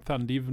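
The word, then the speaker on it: Thandiwe: Jeg må uttale det rett, for hun har jo gått tilbake sitt originalnavn, Thandiv Newton.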